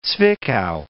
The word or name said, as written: Zwickau